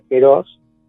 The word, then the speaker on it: kairos: Pero los griegos tienen otra palabra, que es la palabra kairos, que se pronuncia keros, que habla de la calidad del tiempo, que está fuera del espacio-tiempo.